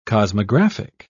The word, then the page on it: cosmographic: British English